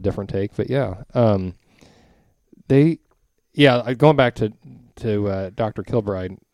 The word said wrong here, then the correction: Kirkbride: Did I say that right? Kirkbride. Sorry. – Kirkbride. Yeah, I was going to say. Kirkbride.